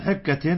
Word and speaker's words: حكة: رخص عبد الرحمن بن عوف والزبير في قميص الحرير في سفر من حكة كانت بهما في سفر، حكة من حكة من هنا للتعليل، يعني لأجل حكة حصلت بأبدانهما، والحكة بكسر الحاء هكذا تلفظ عند العرب، التهاب في الجلد يحمل صاحبه على كثرة حكه، وهي أنواع أشدها الجرب، نسأل الله العافية.